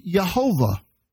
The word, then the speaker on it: Yehovah: and then it's pronounced Yehovah instead of Yahweh.